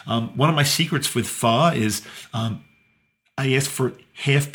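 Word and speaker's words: phở: Pho, which is Vietnamese noodle soup, and it looks like it's spelled P-H-O, pho. But those of you that want to pronounce it correctly, it's actually pho. It kind of goes up a little bit. Pho.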